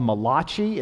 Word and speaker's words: Malachi: So there's a last book in the Old Testament, and it's called Malachi. It rhymes with sriracha, which is a sauce that you can put on food.